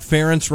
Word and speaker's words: Ferentz: Then, John, but, do you remember when the song came out, Ferentz Rhymes with Parents? – Yes.